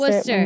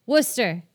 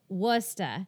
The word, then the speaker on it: Worcester: Worcester. – It. – Worcester. Or if you're from Boston, it's Worcester. I was telling this story earlier when I lived in Boston.